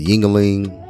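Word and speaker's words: Yuengling: Yingling. You know what that rhymes with. – Yeah, I know. Okay. Dingling. So, yeah, Yingling.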